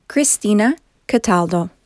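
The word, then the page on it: Cristina Cataldo: English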